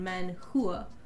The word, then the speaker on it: manhua: Manhua is Chinese. – But H-U-A is pronounced hua still.